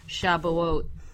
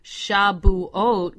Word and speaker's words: Shavuot: First of all, before I get into that, I want to explain to you why I pronounce the word Shavuot with the letter B versus the modern Hebrew, which is a V, Shavuot.